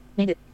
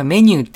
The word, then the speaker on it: メニュー: エチョフォン、メヌ、ボタン。 – 今、メニューって読むべきですけども、メヌって読んでましたね。